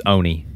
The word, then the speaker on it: Onia: Instead, he will be in Onia. I'm pronouncing that right, I think. – No, it's Oni. The local's called Oni.